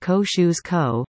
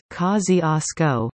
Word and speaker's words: Kosciuszko: Why, Kosciuszko Street, pronounced Ko-shoes-ko, is pronounced in Brooklyn correctly as Kosciuszko, and why, ain't, formerly not a word, was formally added to Webster's Dictionary in 1961.